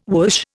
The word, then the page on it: whoosh: Indian